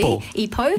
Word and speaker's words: Ipoh: Ipoh.